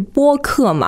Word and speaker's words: 播客: 然后我们这个节目是播客嘛，广播的播，所以这个大家发音上面不要搞错了。